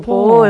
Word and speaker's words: Pau: Pronunciado Pau en francés, pero vamos, Pau para P-A-U, los... Pau como Gasol.